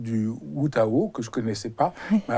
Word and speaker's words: Wutao: Alors le Wutao, et tu le prononces très bien, parce que c'est euh, avec un W, on ne sait pas toujours comment le dire, euh, effectivement, le prononcer en français.